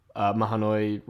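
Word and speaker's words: Mahanoy: They led to the Lehigh Valley Railroad building an extension called the Lehigh and Mahoney. Is that how you say it? Mahoney. Oh, thank you. This is why I have Bill here to correct me.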